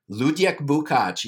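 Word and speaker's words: Luděk Bukač: Ludek Bukac, yeah.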